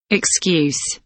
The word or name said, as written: excuse